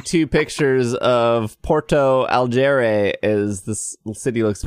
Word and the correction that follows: Porto Alegre: Port Porto Alegre. and that's not even a good pronunciation.